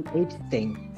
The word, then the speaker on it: Epstein: Epstein, no me acuerdo bien cómo se pronuncia bien el apellido. – Epstein. Jeffrey Epstein.